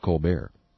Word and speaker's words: Colbert: And you need to know that there's a T on the end of Colbert. He doesn't pronounce it.